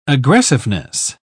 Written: aggressiveness

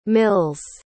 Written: mils

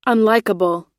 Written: unlikable

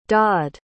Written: daud

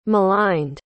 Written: maligned

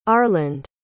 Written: arland

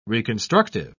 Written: reconstructive

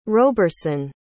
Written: roberson

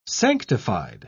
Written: sanctified

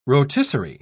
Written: rotisserie

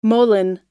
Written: moline

